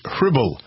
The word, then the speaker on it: Hribal: the story of Alex Hribal. I believe that's how you pronounce his name. H-R-I-B-A-L, right? – Uh-huh.